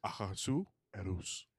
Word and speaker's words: Ahasuerus: A Ahasuerus. – Ahasuerus. That's why there's that dash there. It's a break. The break.